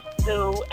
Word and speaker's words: Stew: Okay, my social media is Sweats by Stu, and Sweats, S-T-E-W, and that is also my Twitter name, and my Snapchat name is Do It For Stu, and also Stu is spelled S-T-E-W.